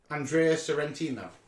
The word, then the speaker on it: Sorrentino: This time, Andrea Sorrento. Sorry, Andrea. Can't say his name. You have a go. – Let me see. What am I looking? Andrea Sorrentino.